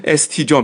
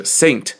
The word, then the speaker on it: Saint: 比方说圣约翰，你看到这 ST 一点，然后后面这个点是句号的这个位置啊，然后再是 John ST John，当然念的时候不念 ST John，你念成 ST John 变成 ST 股票了，对不对？ ST 念的就是 Saint Saint John, Saint John, OK，叫圣约翰，圣约翰啊，所以这个 ST 一点就是 Saint 的意思。